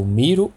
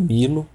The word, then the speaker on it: Milo: Que no caso é o Miro ou Milo, né? Você pode pronunciar das duas formas.